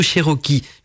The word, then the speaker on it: Cherokee: créée Rocky, je, je sais pas je prononce bien, Cherokee, bah, Cherokee ou Cherokee, voilà c'est plutôt Cherokee,